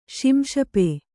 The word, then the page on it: ಶಿಂಶುಪ: śiśupa